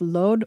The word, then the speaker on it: Lod: Daniela, the center of these internal clashes is in the city of Lod, or Lid as Arabs pronounce it, just 10 minutes outside of Tel Aviv.